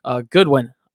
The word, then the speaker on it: Goodwin: Hopefully they can get some of that speed going with Darnell Mooney, Demir Bird, Marquise Godwin. Goodwin. I always say Godwin. Goodwin. I've got to get into a better transition of continuing to say his name the proper way.